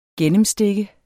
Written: GENNEMSTIKKE

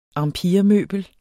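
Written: empiremøbler

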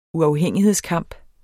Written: uafhængighedskamp

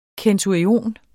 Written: centurion

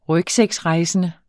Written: rygsækrejsende

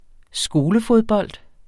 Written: skolefodbold

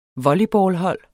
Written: volleyballhold

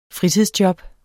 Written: fritidsjob

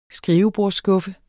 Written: skrivebordsskuffe

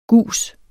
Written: gus

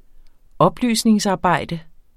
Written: oplysningsarbejde

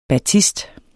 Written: batist

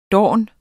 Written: dorn

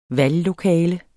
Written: valglokale

